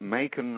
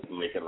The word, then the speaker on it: Macon: Did you realize that Macon rhymes with bacon? – Yeah, I did realize Macon rhymes with bacon, which is a good thing.